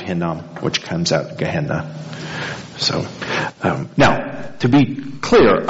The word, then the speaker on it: Gehenna: Gehenna, yeah.